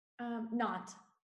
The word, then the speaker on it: Nantes: Nantes. They dropped that.